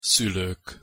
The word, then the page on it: szulok: Hungarian